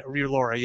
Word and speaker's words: Relora: There's actually a product out there called, I think it's pronounced Relora. I can't remember. Relora, yeah, R-E-L-O-R-A, that helps lower cortisol.